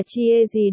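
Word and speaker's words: chia gì (茄子): Hai, gia tử, nghĩa là cà tím, phát âm là chia gì đây là từ các bạn Trung phát âm khi chụp ảnh.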